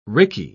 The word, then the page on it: rickey: British English